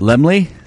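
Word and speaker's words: Laemmle: How's it pronounced? Lamar Theater. L A E Lamar. Oh shit. – Lemley. – No. Is it Lemley? Oh Lemley. My god! I sound like such an illiterate. Lemley Theater.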